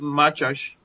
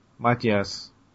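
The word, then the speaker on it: Mátyás: So, You actually pronounce it Matyash. – Matyash.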